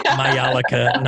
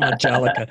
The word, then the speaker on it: Majolica: Besides pronouncing Majolica correctly, it's pronounced Majolica, not Majolica.